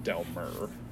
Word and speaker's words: Delmar: I mean, it's spelled Delmar, M-A-R, but I think they all pronounce it Delmer.